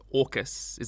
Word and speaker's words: AUKUS: Okay, and to be clear, it's pronounced AUKUS, is that right? – It rhymes with raucous, yes.